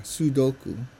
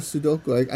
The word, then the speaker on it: Sudoku: Boy, I pronounced it wrong. Sudoku. 2 versions of Sudoku.